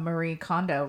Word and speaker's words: Marie Kondo: And I have, like, and that whole concept of, like, sparking joy, it's kind of from Marie Kondo, right?